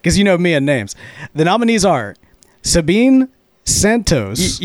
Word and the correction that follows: Sabina: You already butchered it. – Dang it. And John David will read off the nominees. – Yeah, it's Sabina Santos, and we have Tom Eltang, Nate King, Micah Kreider with Yeti Pipes, and Chris Astero.